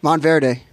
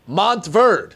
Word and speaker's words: Montverde: How do you say it? – Monteverde. – Nope. – Monteverde. – Nope. – Idiot. – Montverde.